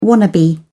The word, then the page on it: wannabe: British English